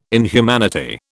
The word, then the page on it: inhumanity: British English